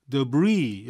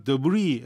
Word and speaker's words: Debris: 근데 Removal of Fallen Leaves and Yard Debris. 여러분 이걸 Debris, S 발음 안 해요.